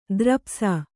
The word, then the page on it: ದ್ರಪ್ಸ: drapsa